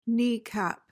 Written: kneecap

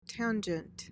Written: tangent

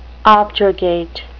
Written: objurgate

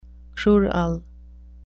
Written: crural

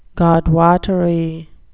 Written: godwottery

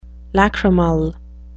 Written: lachrymal

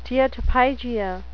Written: steatopygia

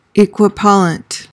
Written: equipollent

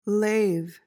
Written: lave

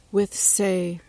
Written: withsay

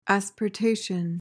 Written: asportation

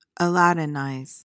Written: aladdinize

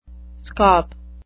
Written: scop

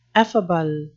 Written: effable

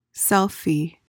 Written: selfie

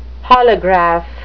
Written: holograph

